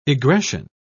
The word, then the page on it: egression: British English